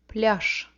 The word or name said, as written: pliazh